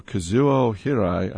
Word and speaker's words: Kazuo Hirai: hand-delivered a letter to Sony CEO Kazuo Hirai,